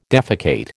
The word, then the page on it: defecate: American English